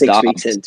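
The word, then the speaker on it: Doubs: from Romeo Dubs, it's looked pretty good so far. – I think it's pronounced Dubs, right? – Yes, it is Dubs, sorry.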